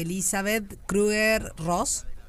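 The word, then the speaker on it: Elisabeth Kübler-Ross: un amanecer de Elizabeth Kruger Ross.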